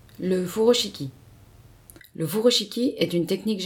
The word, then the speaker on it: furoshiki: Le terme furoshiki se prononce en japonais furoshiki et désigne aussi bien la technique que le carré de tissu.